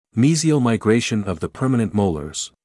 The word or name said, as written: mesial migration of the permanent molars